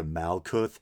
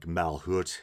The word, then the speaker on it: Malkuth: And then in his song Station to Station, he sings, Here we are, one magical movement, from Kether to Malkuth. And I think the Hebrew pronunciation is something like Malhut or something like that.